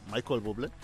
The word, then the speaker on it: Michael Bublé: ¿Cómo se dice Michael Bublé o cómo se dice? – Michael Bublé. ¿Eh? Sí, Michael Bublé.